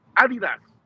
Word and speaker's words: Adidas: Y decirle, si oye, Adidas, lo que yo Adidas, creo que debe de ser, escucha, hazlo así. – Escucha. Adidas.